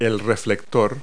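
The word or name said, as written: reflector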